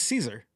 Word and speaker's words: Cesare: It was Caesar. That's how you say it in Italian.